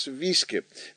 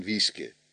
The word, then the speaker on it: Wiske: En in Vlaanderen schrijven ze dat als Wiske. En daarom moet je Wiske, ja die van Suske en Wiske, die Wiske moet je dan ook eigenlijk uitspreken als Wieske. Het is Suske en Wieske.